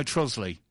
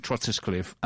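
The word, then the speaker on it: Trottiscliffe: Do you know Trosley? which is spelt Trotters Cliff, to be fair. – It is. – Yeah, and Jules always called it Trotters Cliff, and I said it was Trosley, and she just would not believe me.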